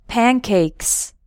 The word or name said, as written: pancakes